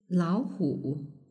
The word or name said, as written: laohu